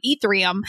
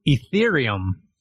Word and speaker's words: Ethereum: Ethereum. – I don't even think you're saying it right. I think it's Ethereum.